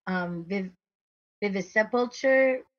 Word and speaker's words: Vivisepulture: Vivisepulture. It's one word. VIVISEPULTURE. I'm just having trouble, like, yeah. Vivisepulture.